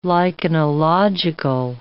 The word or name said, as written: lichenological